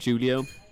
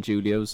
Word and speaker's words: Julio: YouTube is Kieran Julio. I'm the only one on there called Kieran Julio, so it's kind of a blessing that. My SoundCloud's Kieran Julio, hashtag KJ. Julio spelled J-U-L-I-O. Kieran is spelled K-I-E-R-A-N.